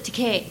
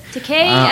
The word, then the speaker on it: Takei: It's pronounced with an A. If it's A-I, it's I. – I think he pronounces Takei. – He pronounces it Takei. – Takei. – Takei as in gay.